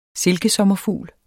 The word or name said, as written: SILKESOMMERFUGLE